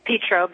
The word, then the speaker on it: Petro: It's Petro, but everybody does it.